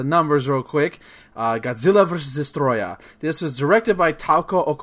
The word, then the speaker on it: Destoroyah: This is Godzilla versus Destoroyah, because you have to pronounce it right. – Destoroyah. – You can't just say Destoroyah, because that just sounds lame. It's you don't want to say his English name, which is just Destroyer. So it's Destoroyah. You gotta accent it a lot. You gotta Japanese it a lot.